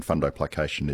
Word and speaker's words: fundoplication: He's asking about fundoplication.